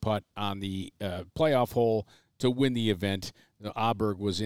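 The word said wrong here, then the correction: Åberg: in that Aubert was in that playoff. Is essentially from the Ryder Cup when he said, no, my name is actually pronounced Aubert, not Aberg, Aberg, what have you. It's Aubert.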